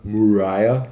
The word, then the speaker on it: Mariah: Na, aber die ähm, Mariah Carey, äh, Mariah, kann man so schön sagen? Ja, so, so spricht man es aus. Ich ja. glaube, so spricht man es im Original aus.